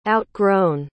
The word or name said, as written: outgrown